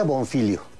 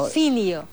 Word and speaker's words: Bonfiglio: Se pronuncia Bonfilio. – Ajá. – O sea, la que no se pronuncia. – Ok. – Es italiano. – Bonfilio.